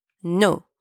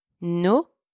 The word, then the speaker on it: nos: You use nos and don't pronounce the S, it's silent. For example, nos parents, our parents.